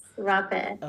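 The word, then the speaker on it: rapé: Rape. So it's R-A-P-E, like rape, mm-hmm. but there's like that little squiggly thing on the E.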